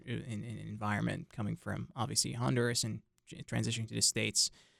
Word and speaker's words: Honduras: Juan is a student here at NC State University studying business administration, and he is from Honduras, or Honduras. That's how it's pronounced in Spanish?